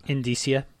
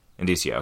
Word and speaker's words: indicia: is it Indicia or Indicia? How do you say that? – Indicia. – Indicia, okay.